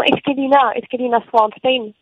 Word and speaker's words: Esquelina Svenstern: Esquelina Svenstern, c'est comme ça qu'on dit ? – Comment ? – Svern, j'arrive pas à le prononcer justement, c'est peut-être pour ça d'ailleurs que tu l'as retiré ton nom de l'album. Comment c'est ton nom exact ?